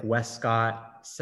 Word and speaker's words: Westcott: And so I felt like I wanted to definitely do that with, you know, with the casting and the characters' names, like Westcott, that rhymes with Prescott, Sidney Prescott, which, you know, not many catch that.